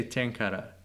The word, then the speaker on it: tenkara: So how would you say tenkara? – Tenkara.